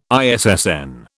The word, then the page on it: ISSN: British English